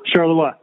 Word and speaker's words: Charleroi: Did I pronounce that correctly, Charleroi? – Charleroi, yep, very good.